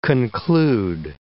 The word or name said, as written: conclude